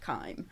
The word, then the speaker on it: Kiem: And I just said, look, it doesn't matter, but it's actually pronounced Kiem.